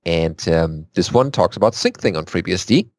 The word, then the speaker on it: Syncthing: Next thing is SyncThing on FreeBSD.